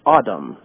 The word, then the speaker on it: Adam: It's a Hebrew Strong 120, which is like, it's like Adam, is like in Hebrew, how you pronounce it.